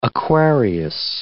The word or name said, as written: Aquarius